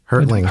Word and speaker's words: Hertling: Do I pronounce your last name right? Hertling? – Hertling. – Hertling, okay.